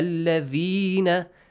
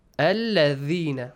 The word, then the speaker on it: alladhina: Wamakhtalafa alladhina dhal. How do you pronounce it? Alladhina, right? – Yes. – How? – Alladhina. – Dhal. Yeah, bite your tongue, yes? The tip of your tongue.